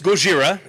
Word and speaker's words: Gojira: Okay, so it's Gojira. It's G O J I R A, um, and you know that is the Japanese. Well, the correct way to say his name.